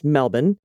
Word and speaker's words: Melbourne: My partner, who is from LA, and I live near Melbourne, which of course is pronounced Melbourne.